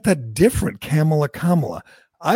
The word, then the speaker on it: Kamala: And he warned, and he told everybody on Fox, you better get her name right, because it's not Kamala, it's Kamala, which...